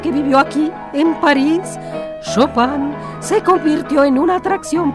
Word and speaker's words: Chopin: Es Chopin, Pili. Se pronuncia Chopin.